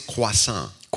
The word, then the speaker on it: croissant: Wait, how did you say that word? – Croissant. I actually pronounce it right. It's croissant.